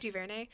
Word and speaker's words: DuVernay: case, Man, Ava DuVernay is just like, am I pronouncing her name right? Yes. DuVernay.